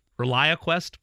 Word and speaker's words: ReliaQuest: The Relia Quest Bowl. Okay. ReliaQuest Bowl? – I believe that'd be ReliaQuest, yes.